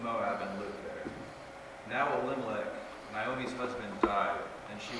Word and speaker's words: Elimelech: The man's name was Elimelech. Am Am I saying that right? – Close enough.